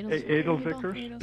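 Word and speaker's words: Edelzwicker: And the wines are not, they're Gewurztraminers, they're Eldervickels, am I saying that right? – Eldervickels. – Edelsvicker? – Yeah, Edelsvicker.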